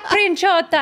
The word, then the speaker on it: Princiotta: Actually, Princiota. I mean, that's the other way.